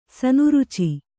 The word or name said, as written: ಸನುರುಚಿ